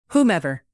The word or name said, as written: whomever